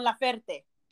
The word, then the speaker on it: Laferte: Actually, yo pensé que era Mon Laferte, y apparently es Mon Laferte. – Laferte, ok. – Laferte, okay. Se pronuncia Laferte.